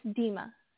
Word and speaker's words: Dema: It's Dema. – Dema. – Yeah. Just like Tina.